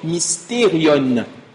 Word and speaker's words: mysterion: Le mot mystère vient d'un mot, on l'a dit la semaine dernière, un mot grec qui se prononce mysterion, qui veut simplement dire secret.